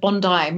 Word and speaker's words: Bondi: By the way, nice pronunciation of Bondi. Most Americans think it's Bondi.